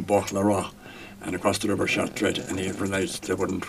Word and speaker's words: Chartres: About your trip to Chartres, is it? – Chartres. – Sorry, my pronunciation isn't very good.